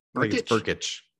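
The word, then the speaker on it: Brkic: There's basically three kickers to look for: Cade York from LSU, Gabe Bursick, I think is how you say his name, Berkich. – From Oklahoma. – Berkich.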